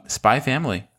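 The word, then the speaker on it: Spy × Family: Spy Family. – Spy Family. – Very good. – That's it. We don't pronounce the X in it.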